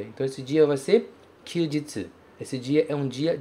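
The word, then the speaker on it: kyūjitsu (休日): E ele é pronunciado kyūjitsu.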